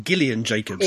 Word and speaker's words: Gillian: I believe she pronounces it Gillian Jacobs. – It is Gillian, yeah.